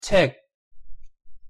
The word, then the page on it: 책: Korean